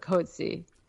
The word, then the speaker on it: Coetzee: I think you pronounce it Coetzee. – Okay. – Everyone pronounces it differently, but I listened to him say it on a YouTube video.